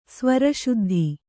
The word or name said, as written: ಸ್ವರಶುದ್ಧಿ